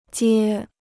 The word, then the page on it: 接: jiē